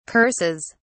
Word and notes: curses: British English

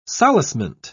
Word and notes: solacement: British English

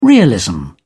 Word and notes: realism: British English

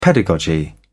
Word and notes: pedagogy: British English

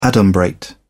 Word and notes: adumbrate: British English

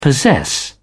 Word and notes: possess: British English

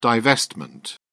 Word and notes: divestment: British English

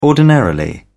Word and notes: ordinarily: British English

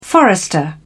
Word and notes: forester: British English

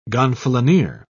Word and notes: gonfalonier: British English

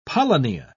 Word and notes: polynya: British English

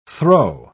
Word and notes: throe: British English

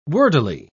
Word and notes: wordily: British English

